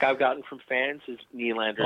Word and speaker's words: Nylander: But Ashton, do you realize here we're talking to the very pro, the very guy that I think every time I say William Nylander, I have to say it like Rossi does, Nylander. And I can't say it like you, but Ashton, you know what I'm saying? – Yeah.